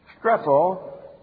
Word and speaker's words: strepho: Epistrepho is the word, and it comes from strepho, which comes, wait a minute, put the R in here, comes from S-T-R-E, the P-H sound, long O, comes from strepho, which in turn comes from strepho, which is to turn, to turn, to revolve, to turn around.